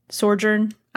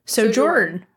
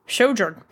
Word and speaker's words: Sojourn: But new damage hero, Sojourn. Yeah. I butchered that. – I think it's Sojourn. – Sojourn.